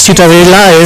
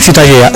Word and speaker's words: Ciutadella: Para mí la ciutadella es... ¿cómo se pronuncia? – La ciutadella. – Ciutadella.